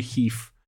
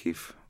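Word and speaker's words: HEIF: Mislim, da se uradno izgovori HIV. HIV. – Ok, HIV.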